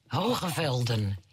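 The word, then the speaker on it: Hoge Veluwe: There's a park to the north, which I won't pronounce properly, Hoogeveldhoven. – Hoogevelden, yes. – But, yeah, your pronunciation's way better than mine.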